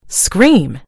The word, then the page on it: scream: American English